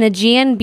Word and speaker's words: Aegean: How are you pronouncing that? – Aegean. A-E-G-E-A-N. – Aegean. – Aegean. I swear I'm correct. – I thought it was the Aegean Sea.